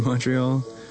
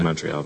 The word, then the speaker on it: Montreal: You don't pronounce the T in Montreal, but... – Well, I do. I'm from America. – No, that's not how you say Montreal.